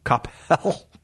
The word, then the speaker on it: Coppell: Coppell.